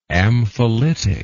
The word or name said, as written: ampholytic